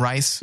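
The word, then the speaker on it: Rhys: Is that how you say his name, Rice? – Yeah.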